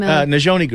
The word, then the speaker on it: Nizhóni: Nijoni Girls. Nijoni.